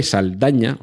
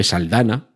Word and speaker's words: Saldaña: Zoe, eh, Zoe Saldaña, Zoe Saldana, eh, se supone que se escribe con ella, pero nunca lo escriben con ella en ningún sitio,